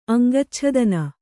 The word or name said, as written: ಅಂಗಚ್ಛೇದನ